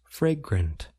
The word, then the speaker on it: fragrant: A few words about how to pronounce fragrant. It involves two vowel sounds, a and a. We generally place stress on the first part of the word, fray. It involves the a vowel sound.